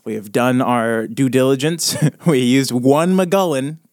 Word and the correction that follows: Mulligan: Not McGullen. What is it? How do you say Mulligan? – It? Mulligan.